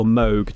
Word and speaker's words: Moog: So a lot of people have heard of the name, perhaps heard of the name Moog or Moog, depending on how you pronounce it, but M-O-O-G, you might see in the t-shirts.